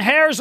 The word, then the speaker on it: heirs: He said, "Today, church, I want to talk about being heirs of God." I said, "What?" I was going to say silent H.